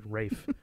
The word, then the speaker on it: Ralph: Maybe it's because his name is spelt Ralph, but he makes people pronounce it Rafe.